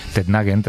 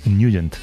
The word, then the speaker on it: Nugent: Hombre, siempre lo hemos llamado en el barrio Tednagent o Tednagent. – Claro, ¿verdad? Yo era de Tednagent. – Pero la pronunciación correcta es Nugent.